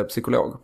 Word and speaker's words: psykolog: Jag pluggar till eh, psykolog på Karolinska. Och du uttalar det med P, psykolog.